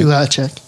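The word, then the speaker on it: Duhatschek: Eric Duhaschek, I'm hoping I'm pronouncing that right. – Duhaschek.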